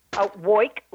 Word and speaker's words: Woik: Woik, like I woik all day and woik all night.